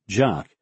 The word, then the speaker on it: JOC: Colonel Mulholland's Joint Operations Center, or JOC, pronounced Jock,